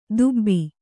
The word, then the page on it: ದುಬ್ಬಿ: Kannada